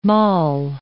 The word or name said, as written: mall